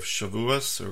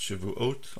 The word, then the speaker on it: Shavuot: So this is describing this holiday of Shavuos, or pronounced Shavuot, also known as the Festival of Weeks, that comes 7 weeks after Passover.